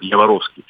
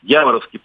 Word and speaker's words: Яворовский: И еще раз хочу напомнить, что вот этот вот Яворовский полигон часто делают неправильное ударение, потому что люди не знают топонимики западноукраинской, называют Яворовский. Яворовский полигон, на котором мы в 8 классе проходили военную подготовку, это буквально 7 километров до границы с Польшей.